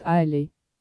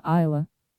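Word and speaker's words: Islay: Название острова англичане произносят «Айлей», а шотландцы исключительно «Айла».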